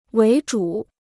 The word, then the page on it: 为主: wéi zhǔ